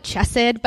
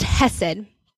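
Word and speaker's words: hesed (חסד): It kind of looks like chesed, but it's a Hebrew word, and it's pronounced hesed.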